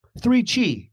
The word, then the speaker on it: 3Chi: 3Chi, it rhymes, is the industry leader in Delta-8 THC products.